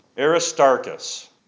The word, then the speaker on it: Aristarchus: Aristarchus, that's how you say it.